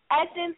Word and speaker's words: Essynce: Essynce, my name is spelled E-S-S-Y-N-C-E, and then Couture is C-O-U-T-U-R-E.